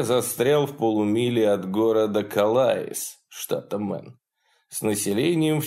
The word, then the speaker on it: Калаис: Местные произносят «Калайс» как «Кол-Айс».